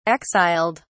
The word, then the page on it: exiled: British English